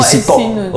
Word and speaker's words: sitok: S-I-N-E. – Sini is where. – Oh. – So how do you say sini? – Sini is sitok. – Sitok. – Yes. – T-O-K. – S-I-T-O-K.